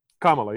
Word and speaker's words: Kamala: it's Kamala, correct? – Kamala, yeah. Kamala, okay. Yeah. Like, the punctuation mark and then, yeah.